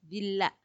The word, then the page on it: dilha: Macuiltianguis